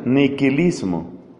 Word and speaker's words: nihilismo: Eso hoy se llama nihilismo, con una H intermedia, que a veces se pronuncia por su raíz latina, nihilismo.